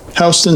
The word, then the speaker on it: Houston: By one account, it was invented in the 1860s by a bartender named Black at a bar on Broadway near Houston Street, but who knows? – Kudos to you for your pronunciation of Houston.